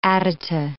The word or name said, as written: adyta